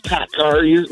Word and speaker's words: PACCAR: Am I saying that right, or do people say PACCAR? – It's PACCAR. You're saying it right.